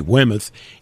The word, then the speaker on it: Weymouth: We go to the very prim and proper New England colony of Weymouth, Massachusetts. – Weymouth, how do you spell that? – W-E-Y-M-O-U-T-H. Not Plymouth, but Weymouth, Weymouth.